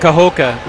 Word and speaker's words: Cahokia: Cahokia.